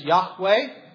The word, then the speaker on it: Yahweh: most people pronounce it as Yahweh, or sometimes a little bit easier, Yahweh.